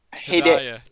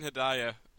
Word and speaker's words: Hedaya: Dan Hedaya. Dan Hedaya. – I'm not even going to. You say it. – It's Dan Hedaya, known as Alicia Silverstone's dad in Clueless.